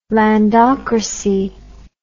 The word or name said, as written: landocracy